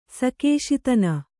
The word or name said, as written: ಸಕೇಶಿತನ